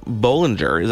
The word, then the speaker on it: Bohlinger: Our next guest is the head of sustainable design at BMW Group, Daniela Bollinger.